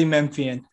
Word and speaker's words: Memphian: Yes, Daily Memphian.